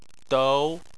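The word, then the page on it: dou: tone 2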